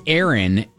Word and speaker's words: Arin: No, my daughter's name is Faith, but it's P-H-A-I-T-H. – So my middle name is Aaron, and it's, how do you think that's spelled? – A-A-R-O-N? – Nope. – A-R-I-N.